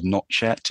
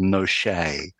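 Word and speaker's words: Nochet: So in my game, out of pure anger, it's not called Notchet; it's called Noshay, just because I. – That sounds good. – I got so angry about this. – That's the French pronunciation, which basically is the correct pronunciation.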